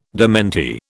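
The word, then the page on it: démenti: British English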